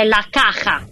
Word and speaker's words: La Caja: Che è La Caja.